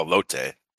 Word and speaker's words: elote: I'm just here to talk about elote. – So is that how it's pronounced? Elote? Mm-hmm. Okay. – No, elote.